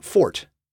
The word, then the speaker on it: forte: Nowadays, most of us mispronounce this word by saying forte, as in, boring listeners with podcasts about word origins is my forte. This pronunciation is used in music, where it is the correct pronunciation and it means to play loudly.